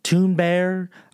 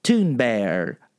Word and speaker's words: Thunberg: Toon Bear. I don't speak German. I don't know. I have heard that it's Toon, not Thune, but I heard someone say Bear instead of Berg.